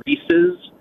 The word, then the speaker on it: Reese's: It is Reese's. It is supposed to rhyme with the word pieces. It is the name Reese, R-E-E-S-E, apostrophe S, Reese's Pieces. Everybody says Reese.